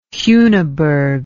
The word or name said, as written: heuneburg